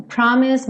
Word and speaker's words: promise: You promise me heaven, promise me heaven. Esse promise também o E não é pronunciado. Esse S tem um leve som puxadinho pro Z, mas você não precisa se preocupar, pode ir direto.